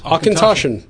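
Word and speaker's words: Auchentoshan: Is that how it's pronounced? – Akintoshin.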